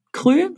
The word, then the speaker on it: Kry: Das heißt, Unternehmen wie zum Beispiel Krü, spricht man es Krü aus, K-R-Y, Teleklinik oder Medgate Deutschland bieten eine Plattform für Ärzte an, um im jeweiligen Gesundheitssystem Videosprechstunden durchzuführen und abzurechnen.